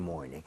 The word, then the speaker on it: morning: Every morning. – 네. – 이왕이면 morning 할 때도 morning보다는 R 좀 땡겨서 네. – more 할 때 R 혀를 많이 당겨서 발음을 해보도록 하겠습니다.